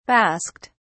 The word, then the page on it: basked: British English